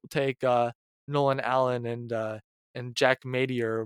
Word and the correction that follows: Matier: Matier, Matra, his name is pronounced, instead.